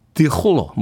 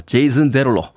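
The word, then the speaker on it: Jason Derulo: 아, 제이슨 데롤로라고 되어 있는데 이게 찾아봤더니 발음상으로는 프랑스어로 드홀로 뭐 이렇게 발음을 해야 되는데 미국 아티스트니까 예, 그냥 미국 발음으로 제이슨 데롤로 원, 투, 원, 미.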